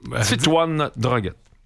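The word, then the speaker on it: Titouan Droguet: Est-ce que j'ai bien prononcé? Titouan Droguette. Voilà.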